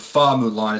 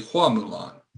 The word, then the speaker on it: Hua Mulan: And also they actually corrected this, I found out that the Chinese pronunciation of Fa Mulan is actually Hua Mulan, H-U-A instead of F-A.